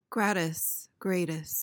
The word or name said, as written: gratis